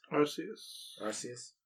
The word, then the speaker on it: Arceus: Arceus.